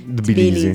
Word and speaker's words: Tbilisi: Cosa è successo martedì notte a Bilisi? Che, perdonerete la pronuncia, Bilisi. – Tbilisi. Tbilisi, va bene.